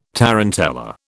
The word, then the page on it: tarantella: British English